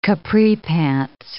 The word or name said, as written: capri pants